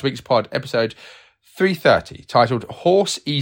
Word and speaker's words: Horsey: Maria Wicks, I am so disappointed Horsey isn't pronounced like a horse.